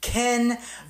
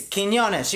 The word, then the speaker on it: Quiñones: Quiñones. I have to say, like, Quiñones. – Yeah, like Quiñones. So, like, she was like, oh, I can't say your last name. Quiñones, Canzones. Like, I was like, okay, that's underwear, but it's Quiñones.